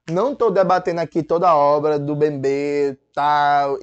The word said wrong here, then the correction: Mbembe: Bebê não, Mbembe, né?